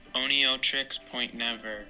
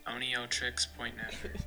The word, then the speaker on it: Oneohtrix Point Never: Oneohtrix Point Never. – Cool, got it. – Oneohtrix Point Never.